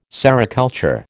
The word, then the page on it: sericulture: American English